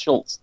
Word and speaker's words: Schultz: Well, the it is still pronounced Schultz, though, because that's how German works. – Yeah, like it's so weird. – Yeah, the ending Z is still is that sound.